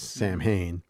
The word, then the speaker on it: Samhain: That's why in Halloween 3, it's Silver Shamrock and, you know, all that Ireland Samhain, which is Samhain. In English, it looks like Samhain, but it's pronounced Samhain.